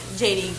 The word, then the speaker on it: Jhadee: So if you just type in J-H-A-D-E-E on any social media platform, I am the only JD Gordon in the world.